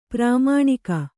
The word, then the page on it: ಪ್ರಮಾಣಿಕ: pramāṇika